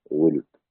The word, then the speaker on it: ولد: نعم، وقرأ الحسن والجحدري وقتادة والزر بن أبي إسحاق وطلحة ورواية عن أبي عمرو وولده بكسر الواو وسكون اللام ولد. نعم، هذه اللغة معروفة إلى الآن، يوجد في بعض بلاد المغرب والشناقطة شنقيط حتى ينطقونها ولد.